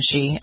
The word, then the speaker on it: Xi: Is Xi, right? Isn't that how you pronounce it? – Very good. I'm very impressed.